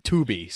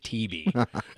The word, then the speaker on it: Tubi: So I just released The Lost Chronicles on Tubi there a if I said that right. Tubi, sorry. I always say it TB.